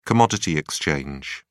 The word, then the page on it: commodity exchange: British English